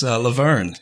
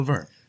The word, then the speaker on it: LaVergne: Oh, you're close, LaVergne. Yeah, like LaVergne and Shirley. Bryan LaVergne.